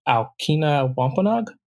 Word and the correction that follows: Aquinnah Wampanoag: Uh, Aquinnah Wampanoag, yes. – Aquinnah Wampanoag.